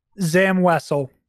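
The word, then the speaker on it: Zam Wesell: Zam Wessel.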